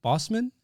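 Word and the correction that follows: Boseman: Am I saying that correctly? – Bozeman. – Okay.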